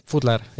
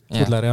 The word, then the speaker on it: Foodler: Eks igaüks võib ise hääldada nii, kuidas soovib, aga meie ise kutsume Foodleriks. – Foodler, jah, selline õhuline ja ühekordset tähtede hääldus selline. Foodler, jah.